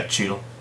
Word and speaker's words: Cheadle: Is that how you say his name? Cheadle? – Yeah, Cheadle.